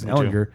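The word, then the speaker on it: Ehlinger: Now that Sam Ellinger is in there, I've been saying it wrong a bunch of times. Ellinger.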